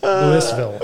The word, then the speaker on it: Louisville: He's got to learn how to say Louisville. – Yeah, look at this guy from the West. – I was worried about mispronouncing it. That's funny. Pointing that out. – Go down there and call it Louisville. – Louisville. – Exactly. That's exactly right.